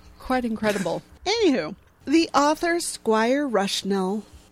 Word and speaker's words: Squire Rushnell: Squire Rushnell. – Okay, worth the ask. – Okay, I know I'm pronouncing the first word right, which is squire, but the second word is spelled R-U-S-H-N-E-L-L.